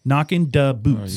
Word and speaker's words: Knockin' Da Boots: Duh, spelling D A, not a T H E. Da Boots. – And Knockin'. – So you need to say that right. – Apostrophe. – Yeah, there's no G on the end of that. There's no G on the end of that word. So Knockin' Da Boots.